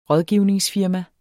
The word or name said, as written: rådgivningsfirma